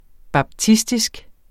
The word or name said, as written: baptistisk